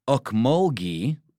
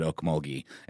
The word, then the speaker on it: Ocmulgee: pop in with a little Georgia-centric thing, saying here in Georgia, they call the old muddy river Okmulgee, Okmulgee. Okmulgee. – And that's spelled O-C-M-U-L-G-E-E. – But the funny thing is, I am from Georgia, and I've always said Okmulgee, and I've heard it pronounced that way, but whatever you say. And then ghee, like clarified butter, Okmulgee.